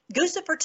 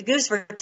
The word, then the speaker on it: Guccifer: But the Guccifer 2 claimed credit for hacking. But there's no such, it's just a bogus. But they called it Guccifer 2, maybe because it rhymes with Lucifer.